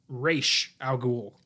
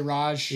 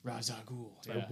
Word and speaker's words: Ra's al Ghul: And for the record, it is pronounced Ra's al Ghul. – A lot of people say Raj. – Yeah, because Batman Begins overlooked that little detail. – Ra's al Ghul.